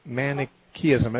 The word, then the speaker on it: Manichaeism: M-A-N-I, Manichaeism. It's, I think, C-H-E-A-N-ism.